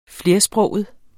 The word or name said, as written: flersproget